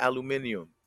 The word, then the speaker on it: aluminium: He used to say, pass me the aluminium. Aluminium. – Hey, that's how you say it in some countries, man. Hey, they go back aluminium. To what is that? Aluminum? – Aluminum, yeah.